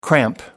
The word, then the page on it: cramp: American English